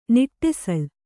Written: ನಡುಸಾಲೆ

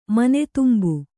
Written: ಮನೆದುಂಬು

